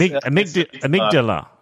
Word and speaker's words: amygdala: How do you pronounce it? – I'm pretty sure it's amygdala.